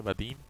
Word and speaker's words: Budin: Se llama Tom, se pronuncia Badin, pero se escribe Budin.